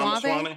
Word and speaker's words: Ramaswamy: Is it Ramaswamy? – Ramaswamy.